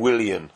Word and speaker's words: Willian: Chelsea doubled their lead when Williams' shot deflected in for 2-0. That's the Brazilian, Willian. Sorry about the pronunciation.